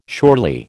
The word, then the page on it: surely: American English